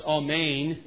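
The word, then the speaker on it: Amen (ἀμήν): Now, that word, it's actually pronounced Amen in Greek.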